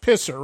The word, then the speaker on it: PSSR: You spell it P-S-S-R, but it's actually pronounced Pisser, right?